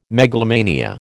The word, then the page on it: megalomania: American English